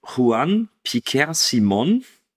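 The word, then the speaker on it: Juan Piquer Simón: Gedreht wurde der Film von Juan Piquer Simon. Würde ich jetzt mal behaupten, dass es so ausgesprochen wird.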